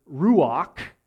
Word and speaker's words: ruach: In fact, in Hebrew, the word ruach, that's how you pronounce it, is the same word for spirit and breath and wind.